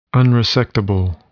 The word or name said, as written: unresectable